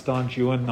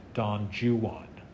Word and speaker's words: Don Juan: And then he says, "I think we'll have to go back to our old friend Don Juan." And so throughout the poem, he's giving a sort of aggressively English pronunciation, because it's funny not to say Don Juan, but to say Don Juan.